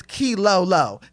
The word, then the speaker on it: Keylolo: Okay, y'all ever seen Martin whenever they win the car and Key Lolo's in the back and Tommy, he says her name wrong and she goes, it's Key Lolo. K-E-Y-L-O-L-O.